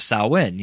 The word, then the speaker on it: Samhain: But again, it's the whole idea of sacrifice on the night of Samhain, you know, which again, I love that this movie properly pronounced that.